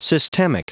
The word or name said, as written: systemic